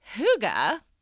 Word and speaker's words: hygge: Well, hygge, which is spelled H-Y-G-G-E, is a Danish word for the art of cozy.